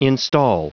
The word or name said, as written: instal